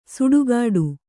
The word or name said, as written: ಸಿಟ್ಟುಗುಟ್ಟು